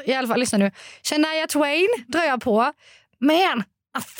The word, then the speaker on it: Shania Twain: Shania Twain. Uttalar man henne så? Nej, det gör man inte. Jo, det var bra. Nej, det tror jag inte var bra. Oh, ja. Shania, Shania Twain.